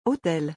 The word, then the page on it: hotel: French